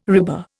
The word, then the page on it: RIBA: Indian